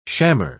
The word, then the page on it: shammer: British English